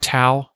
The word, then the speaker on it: Tao: And the reason there's a question about it is the correct pronunciation of the Chinese word is Tao. – Right. – But the way most people say it is Tao.